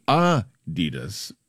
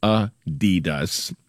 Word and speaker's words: Adidas: It's supposed to be Adidas, with a slight emphasis on the first syllable, not Adidas, stressing the second.